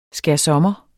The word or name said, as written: skærsommer